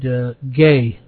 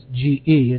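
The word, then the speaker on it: ge (γῆ): But normally, God uses the word ge, which is spelled G-E.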